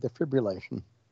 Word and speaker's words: defibrillation: He got care on the field, including defibrillation. Did I say it right? – Not bad. Defibrillation.